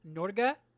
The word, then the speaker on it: Norge: Fun fact, the helicopter that said, they're like, it says Norge. It's pronounced Norga, and it's Norwegian for Norway.